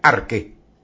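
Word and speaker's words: Arque (ἀρχή): Se escribe Arche con CH, pero se pronuncia Arque y significa principio o comienzo.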